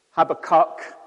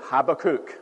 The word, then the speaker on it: Habakkuk: Habakkuk, Habakkuk, Habakkuk, three different how to pronounce videos on YouTube gave me those three different options, so take your pick. We call him Habakkuk, where I come from. That's the Scottish way.